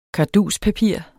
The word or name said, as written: karduspapir